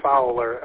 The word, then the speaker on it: Fowler: Flower? Maybe it's Flower, Michigan. I don't know. I'm probably saying it wrong. But go ahead. – Yeah, I had a pretty good day down. Yeah, so it's Fowler, F-O-W-L-E-R.